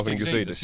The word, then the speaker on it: Gazidis: I can never pronounce his name, the chap who runs Arsenal, Mr. Gazidis. – Gazidis, yes.